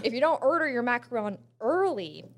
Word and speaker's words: macaron: And I've been saying it wrong. It's not a macaroon.